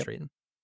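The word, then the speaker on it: Straiton: Is that how you say his name? – Yep. – Straton.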